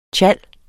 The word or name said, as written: TJALD